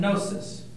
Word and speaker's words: gnosis: Pro and gnosis. Actually, the G should be included. Gnosis. Know here means to know in Greek. The Gnostics were the people who knew. To know is gnosis. Knowledge is gnosis.